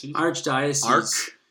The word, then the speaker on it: Archdiocese: How do you say that? – Archdiocese. – The Ark of the Diocese.